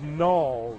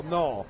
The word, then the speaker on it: Nall: My name is spelled N-A-L-L, but 9 out of 10 times they call it Nell. And I'm like, it's Gnaw. Rhymes with Maul, Gnaw.